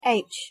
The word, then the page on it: h: English